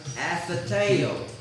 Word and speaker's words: athetēo: The word despise used here comes from the Greek word athetēo. Athetēo. It is spelled A-T-H-E-T-E-O.